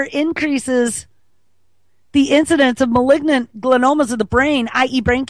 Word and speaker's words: glioma: Oh, malignant gliomas. That's how you say it. Now I see it, how it's spelled, G-L-I-O-M-A.